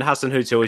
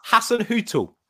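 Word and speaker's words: Hasenhüttl: Hasan Hüttl. Or I suppose it's better with a German accent, but in plain English, I think that's what we'll go for.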